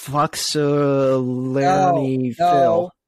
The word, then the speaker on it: Punxsutawney Phil: It's Punxsutawney Phil.